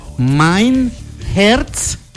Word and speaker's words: Mein Herz: Entonces, esta canción se pronuncia Mein Herz. A ver, repita de mí. – Mein Herz. My Herz.